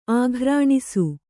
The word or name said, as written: ಆಕರ್ಣಿಸು